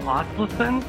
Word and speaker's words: Asleson: I'm here once again with Jay Fischer and Paul Asleson. Is that correct, Paul? – That was good. We're getting close here, yeah.